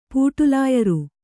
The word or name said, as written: ಪೂಟಲಾಯರಿ